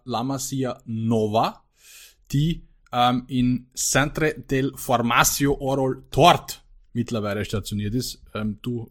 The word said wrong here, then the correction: Oriol Tort: Ich hoffe, ich habe es richtig ausgesprochen. – Ich sag's nochmal. – La Masia Centre de Formacio Oriol Tort. – Ja, klingt gut.